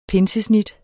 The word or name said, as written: pinsesnit